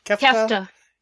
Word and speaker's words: kefta: And they also, the beef, how's it pronounced? Kefta. Yeah.